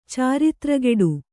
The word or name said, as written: ಚಾರಿತ್ರಗೆಡು